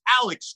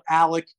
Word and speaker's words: Alex: Yeah, it's spelled like that, but he calls himself Alec. His lawyer calls him Alec. I'm sure his mama called him Alec, so I'm going to call him Alec.